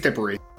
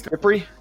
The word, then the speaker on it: Dippre: That being, CJ, is it, you said it was Dippre? – I think it's Dippre. – I think it's Dippre.